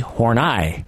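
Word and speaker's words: Horney: Well, I thought it was horny because it looks like horny to me, but it's actually horni.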